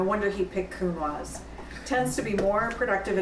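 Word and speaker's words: Counoise: This is a Cunoise. Did I pronounce it right? – Cunoise, yes.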